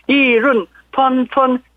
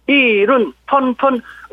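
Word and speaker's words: 이론, 펀펀 (议论纷纷): 중국어로는 사성, 사성, 일성, 일성 써갖고 이론, 펀펀, 이론, 펀펀, 의견이 분분하다입니다. 미중 양국의 용호상박 국면을 우리는 잘 활용할 필요가 있습니다. 이론, 펀펀.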